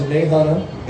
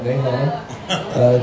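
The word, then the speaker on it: Nahunta: This Friday night, we'll be heading a little bit to the west, a little bit to the south, heading down to Nahanna. Got to say it right. The T is silent. Nahanna.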